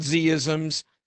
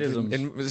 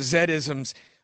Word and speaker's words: Z-isms: Well, it's called Z-isms. I guess that's the American pronunciation. It'll be Zed, right? – Zed-isms. – Zed-isms.